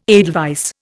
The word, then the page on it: edelweiss: Indian